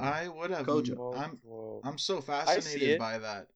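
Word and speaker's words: Kwadwo: It's spelled Q-W-A-D-W-O. We pronounce it Quadwo. But apparently, we were wrong. He wrote in saying, it's time to pronounce my names well, lads. It's Kojo.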